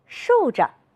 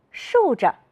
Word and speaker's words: 竖着: 扇子，是的，耷拉，竖着。你发现了什么？有的同学说，老师，后一个字都要读成轻声。你们真会观察，我们再来读一读。扇子，是的，耷拉，竖着。